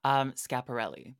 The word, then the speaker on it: Schiaparelli: Schiaparelli.